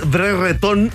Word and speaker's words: Brereton: Y decían Brereton. Burton. Ah, ¿Sabes cómo lo pronuncian los ingleses? ¿Cómo se pronuncia realmente? Y la verdad no tiene ningún futuro. ¿ah? Es Brereton.